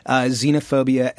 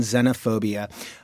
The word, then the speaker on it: xenophobia: There's actually two acceptable pronunciations, and that's what we were testing, xenophobia and xenophobia. Xenophobia is the older pronunciation, but xenophobia has been around for quite a while.